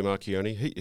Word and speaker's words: Marchionne: Tell us a little bit about Sergio Marchionne. Is that the best way to pronounce it? – Yeah, that's right. Sergio Marchionne, he's a remarkable guy.